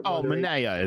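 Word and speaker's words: Manaea: I want to personally because of, what's it, Manea, or how do you pronounce his last name? I know I'm saying it wrong. He's a lefty for the Oakland A's. He's their Manea. It is.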